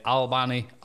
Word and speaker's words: Albany: In Albany, New York. – Albany. – Wow, that's really bad. I had no idea how to fucking pronounce that. – Albany. – Albania, New York. – Not Albania.